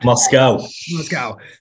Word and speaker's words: Moscow: is it Moscow? Do I have that correct? Or is there, am I mispronouncing that? – Moscow.